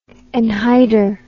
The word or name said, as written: anhydr